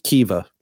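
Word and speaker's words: Kiwior: Yeah, and I don't think he'll give a debut to who my Polish friends have told me it's pronounced Kiva. – Kiva? – Yeah. – Really? – Yeah. Kiever.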